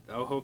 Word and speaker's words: Ojo: Yeah, the Oho Customs. Yeah. – Spell that for them. – O-J-O. – There you go. You don't want them to use an H.